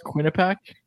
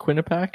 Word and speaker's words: Quinnipiac: Isn't it just pronounced Quinnipiac? Isn't it just Quinnipiac? – Don't worry about it.